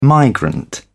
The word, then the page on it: migrant: British English